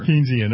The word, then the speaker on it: Keynesian: Yeah, you know, I think what we're seeing is really the end game of Keynesian economics. Am I saying that right? – Yeah. – Thank you. – Yeah, I'm not Keynesian, but whatever. – Keynesian, all right.